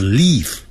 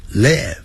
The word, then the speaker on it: live: So they and I live 不要念成 live 短音节 it 短的元音 it it 二三四的 it live live